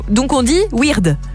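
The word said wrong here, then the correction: Wierde: Non, Vierde.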